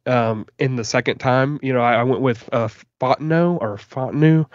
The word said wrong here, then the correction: Fautanu: I'm so sorry for butchering his name. – That's all right. It's Fautanu, and I know that because Joe Shad of the Palm Beach Post also mentioned him for the Miami Dolphins, and he has great pronunciation.